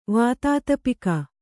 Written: ವಾತಾತಪಿಕ

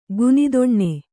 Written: ಘನತನ

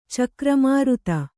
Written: ಚಕ್ರಮಾರುತ

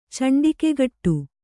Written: ಚಂಡಿಕೆಗಟ್ಟು

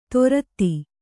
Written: ದೋರ್ದಿ

